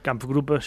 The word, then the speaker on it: Kampfgruppe: Por otro lado, también el Kampfgruppe SS Paper, creo que lo he dicho bien, ¿no? – Kampfgruppe, sí, más o menos.